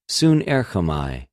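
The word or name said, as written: συνέρχομαι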